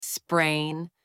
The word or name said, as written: sprain